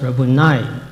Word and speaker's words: Rabboni: Actually, it should be pronounced Rabboni, the accent on the last syllable, which means my teacher.